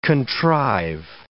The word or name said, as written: contrive